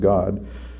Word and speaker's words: Gad: Gad, which is actually pronounced, it's the letters Gimel Dal, it's actually pronounced God, through a deity of fortune.